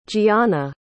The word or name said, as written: giana